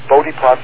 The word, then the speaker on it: Bode plot: the so-called Bode plot, B-O-D-E, or Bode plot, something people pronounce it.